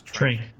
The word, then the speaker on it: Trank: There's that horrible Fantastic Four movie that my eternal enemy, Josh Trask. – Josh Trank.